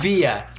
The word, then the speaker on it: Villa: Is that how you say it? Via. – Via.